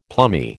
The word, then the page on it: plummy: American English